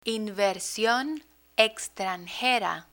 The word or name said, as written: inversion extranjera